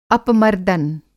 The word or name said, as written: अपमर्दन